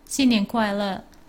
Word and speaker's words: 新年快乐: 新年快乐.